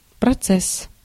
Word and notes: процесс: /prɐˈt͡sɛsː/

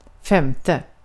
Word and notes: femte: /ˈfɛmˌtɛ/

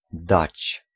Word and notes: give: /ˈɡɪv/

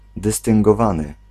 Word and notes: dystyngowany: /dɨstɨngɔ'vanɨ/

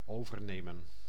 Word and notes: overnemen: [o.vǝɾ.ne.mǝː]

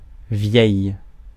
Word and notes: vieil: [vjɛj]; France

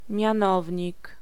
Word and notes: mianownik: /mʹi ̯ãnovʹńik/; /mʲjãˈnɔvʲɲik/

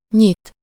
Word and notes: ouvrir: /u.vʁiʁ/

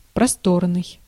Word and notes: просторный: /prɐˈstornɨj/; prostornyi

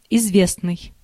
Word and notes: célèbre: France; [se.lɛbʁ]

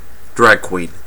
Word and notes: drag queen: /ˈdɹæɡˌkwiːn/

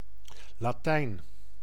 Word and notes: Latin: [ˈlæt.ən]; /ˈlæt.ɪn/; /ˈlæt.n/

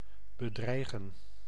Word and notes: menacer: France; [mə.na.se]

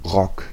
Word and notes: skirt: [skɜː(r)t]; /skɜːt/; /skɜ˞t/; /skɝt/

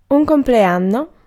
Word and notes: compleanno: [un kɔmplɛˈannɔ]

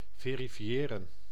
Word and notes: vérifier: France (Paris); [ve.ʁi.fje]

